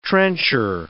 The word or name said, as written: trencher